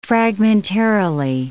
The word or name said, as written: fragmentarily